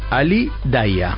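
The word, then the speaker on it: Ali Dia: Bueno, se escribe, el apellido es Dia, pero bueno, los ingleses le dicen Ali Daia.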